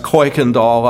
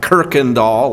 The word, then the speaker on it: Kuykendall: It's pronounced Kuykendall up there, not Kirkendall like you have it here.